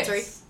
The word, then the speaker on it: Valtteri: How do you say it again? Valtteri? – Yeah. – Okay. Valtteri. That's the one.